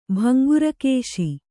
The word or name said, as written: ಭಂಗುರಕೇಶಿ